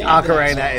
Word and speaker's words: Ocarina: No, I think Ocarina is. I don't know why I said Carney or Cornea.